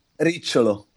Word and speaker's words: Ricciolo: Fabio Riccolo. – Ricciolo. – Riccilo. – Yeah, exactly, because double T and I together are making the sound of CH. So Ricciolo.